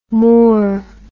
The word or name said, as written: moeurs